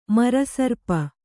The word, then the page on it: ಮರಸರ್ಪ: kannada